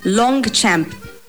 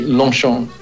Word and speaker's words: Longchamp: Brand number one, Longchamp. – Well, that should be Longchamp.